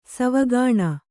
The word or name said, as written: ಸಾವುಗಾಣ್